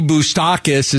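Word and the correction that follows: Bukauskas: You can't even say his name right, for goodness sakes. Yeah. – Bukoskis.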